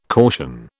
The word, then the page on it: caution: British English